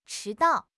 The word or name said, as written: chi2 dao4